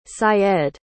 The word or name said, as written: Syed